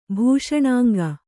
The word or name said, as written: ಭೂಷಣಾಂಗ